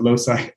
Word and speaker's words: Loci: We Americans pronounce it Loci, but it's L-O-C-I.